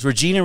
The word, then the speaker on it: Regina: It's Regina, Regina.